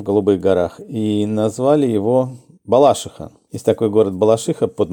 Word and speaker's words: Балашиха: Это я с детства, там все местные говорили Балашиха. Правильно, Балашиха, но это уже такой местный сленг.